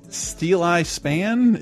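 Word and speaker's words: Steeleye Span: And Tempted and Tried by Steel Eye Span. Am I saying that right? – Yes. – Not Steely Dan.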